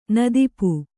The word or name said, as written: ನದಿಪು